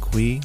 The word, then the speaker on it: Cui: And of course, you can find me, Brian Cui, last name is spelled C U I, on LinkedIn.